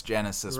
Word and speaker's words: Genisys: Terminator Genisys? – Is that really how they pronounce it? – No, it's Genesis, right. But they spell it stupid. – Yeah, they spell it with a Y.